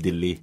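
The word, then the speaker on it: ดิล ลี (दिल्ली): ดิลลีก็คือเดลีเนี่ยนะเดลีเนี่ยเรียกว่าเดลีหรือดิลลีนะอันนี้เขาออกเสียงแบบคนอินเดียเลยดิลลีนะครับ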